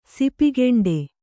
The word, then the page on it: ಸಿಪಿಗೆಂಡೆ: sipi geṇḍe